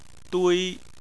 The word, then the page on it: dui: 1